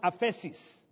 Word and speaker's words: aphesis: The Greek word is pronounced aphesis. It's spelled A-P-H-E-S-I-S. A-P-H-E-S-I-S, aphesis.